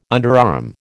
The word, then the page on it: underarm: American English